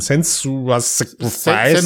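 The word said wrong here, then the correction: Senua's Sacrifice: Senua Sacrifice, genau das ist der ja.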